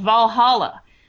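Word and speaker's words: VA-11 Hall-A: I have recently gotten into a game called Valhalla, which is spelled in a super cool way, V-A-1-1, Hall A, which is fun.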